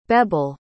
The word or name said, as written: bebel